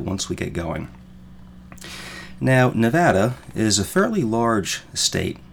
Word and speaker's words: Nevada: Now, before I go any further, I know most of us say Nevada, but the correct pronunciation is actually Nevada. In fact, people in the state of Nevada go so far as to put that little smiley face thing over. I forget what it's called. Looks like a small U. That makes it a short A, Nevada.